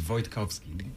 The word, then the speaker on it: Wojtkowski: So it's Renek Wojtkowski. In Polish, I'm originally from Poland. It would be Wojtkowski.